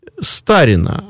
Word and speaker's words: старина: И называлось это «старина» обычно, опять-таки с непривычным ударением.